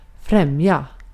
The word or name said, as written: advantage